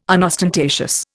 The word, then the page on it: unostentatious: Indian